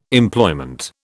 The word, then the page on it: Employment: British English